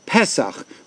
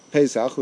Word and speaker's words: Pesach: But parenthetically, the actual name of the Yom Tov is Pesach, with an E under the Pe. You'll never find in the Torah that it has an A sound. So the correct pronunciation is Pesach. Somehow it got corrupted, and it's referred to as Pesach, which is how I'll refer to it. But the real name is actually Pesach.